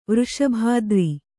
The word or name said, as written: ವೃಷಭಾದ್ರಿ